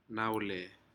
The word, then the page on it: naule: Aneityum